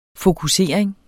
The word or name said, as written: fokusering